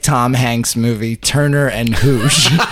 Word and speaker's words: Hooch: Because I just assume Hooch, because it rhymes with pooch.